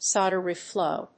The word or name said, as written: solder reflow